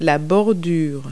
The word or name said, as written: curb